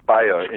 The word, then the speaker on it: Bayer: It would be pronounced Bayer in German.